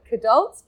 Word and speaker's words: kidult: Kidults? Kidults. How do you say it? – Kidult. You're emphasizing the alt in kidult, which I think does sound dangerously like alt-right.